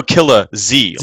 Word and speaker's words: Killers: Pronounce it Serial Killer Z, like Dragon Ball Z.